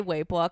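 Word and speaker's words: Weibo (微博): And like I only say Weibo because I know that's how you say it,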